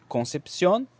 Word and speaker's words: Concepción: Concepción pronuncia-se com, com K, em espanhol na lá, é uma comuna e cidade chilena.